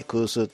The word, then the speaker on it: 古酒: あとは空数とかね、古酒って書いて空数って読むんですけども、そういったお酒もね、製造されているメーカーもあるようですんでね、そのあたりと一緒に食いたまんないでしょう。